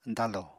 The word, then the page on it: dañlò: apatani